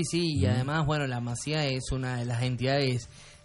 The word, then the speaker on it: Masía: Bueno, contundente la respuesta de Messi a los rumores de la partida de su hijo Thiago a la Masia de Barcelona. – La Masía se dice. Sí, tenés razón, tiene un acento en la I.